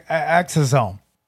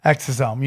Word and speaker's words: exosome: How do I pronounce it? – Exosome. – You made a smile there like I had butchered the word. – Yeah, well, you said like exosome.